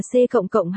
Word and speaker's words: C++: Net, nó không phải là một phần của Net, 2, C cộng cộng, đọc là C cộng cộng hay C++, IPA, CPOPO, là một loại ngôn ngữ lập trình.